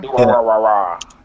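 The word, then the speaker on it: Durarara: And then I've been I just started watching Do Ra Ra. Is that how you say it? Do Ra Ra Ra. – Do Ra Ra Ra.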